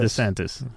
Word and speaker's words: DeSantis: DeSantis.